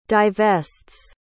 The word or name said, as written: divests